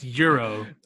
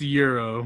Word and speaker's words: gyros: It's pronounced Euro. – That's what I know. – It's pronounced Euro.